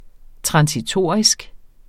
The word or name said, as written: transitorisk